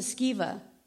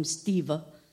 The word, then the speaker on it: Sceva: The sons of Sceva, I said it right this time. I kept calling him Steva.